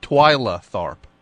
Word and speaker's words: Twyla Tharp: Twilight Tharp? Only the biggest choreographer on Earth, dummy. – Uh-huh. Yeah. Twyla? – What's that? – Twyla Tharp. – What is that? – That's the choreographer's name. – Spell it. – T-W-Y-L-A.